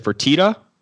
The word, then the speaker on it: Fertitta: Billionaire, how do you pronounce this guy's last name? Is it Fertitta? – Sure.